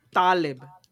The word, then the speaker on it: Taleb: Porque se dice Taleb, es con la tónica en la A y con las apretando poquito las narinas en la T, güey.